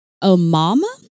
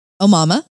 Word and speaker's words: Omaima: Her name is Omaima. – Omaima. – Yeah. Her. – Okay. – O-M-A-I-M-A. She's a, it's Egyptian. – Oh, okay. – Omaima.